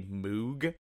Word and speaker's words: Moog: Yeah, it's actually Moog. – Yeah. Side note up next. – Sidebar. – Steve genuinely thought it was called a Moog. Which is dumb. – It's spelled Moog, and also not only did I think that, but I pronounced it that way during a presentation during a college course.